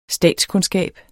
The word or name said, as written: statskundskab